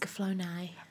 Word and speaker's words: Escaflowne: The Vision of Escaflowne. Am I pronouncing that correctly? – It's Escaflowne.